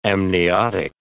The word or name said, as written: amniotic